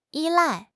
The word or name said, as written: 依赖